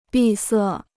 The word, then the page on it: 闭塞: bì sè